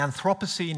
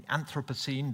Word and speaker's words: Anthropocene: And the Anthropocene, if you like, or the Anthropocene, depending on your pronunciation preference, it is different.